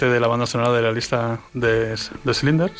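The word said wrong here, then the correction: Schindler: ¿se mm. pronuncia? Sindler. – Sindler.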